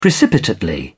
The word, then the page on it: precipitately: British English